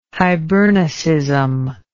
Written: hibernicism

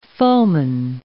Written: foeman

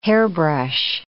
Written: hairbrush

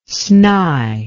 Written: snye

